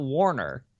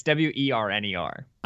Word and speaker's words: Werner: Because it's not Warner. It's W E R N E R. I think it's Werner. Yeah, Pete Werner.